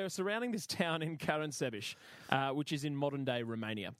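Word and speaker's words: Caransebeș: And they set up camp surrounding the town of Karansebeş. Ooh, I've said that correctly. – Yeah? – Yeah, I checked it. I checked it on the article.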